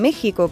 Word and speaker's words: México: Os cuento, la letra X no siempre se, se pronuncia como KS, ¿no? Como en extraño o exclusivo. Pues hay palabras en las que no representa ese sonido, que es el suyo actual, sino el de la J, como en México, que se escribe con X, y Texas, que se dice el estado de Texas, no de Tesa.